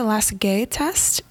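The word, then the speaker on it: Lasègue: The next test I have been pronouncing wrong. my entire career. It's pronounced the Lasague test. It's L-A-S-E-G-U-E. I've been pronouncing Lasague.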